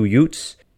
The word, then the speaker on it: Utes: Uh, you got to say it like Joe Pesci and my cousin Vinny. Two Utes, Tom Chambers.